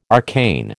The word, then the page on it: arcane: American English